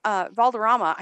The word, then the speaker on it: Valderrama: DJ Cortez plays Seth, his brother, and Robert Patrick shows up in the role that Harvey Keitel was in the series, and Wilmer Valdemar Valderrama. I can never say that right.